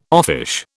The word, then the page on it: offish: British English